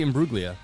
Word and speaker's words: Imbruglia: Is that how you say it? Let me look this up real quick. Torn. This was a classic. Yeah, Natalie Imbruglia.